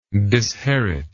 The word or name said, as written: disheritor